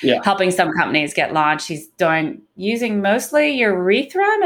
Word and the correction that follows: Ethereum: Am I saying that right? – Ethereum.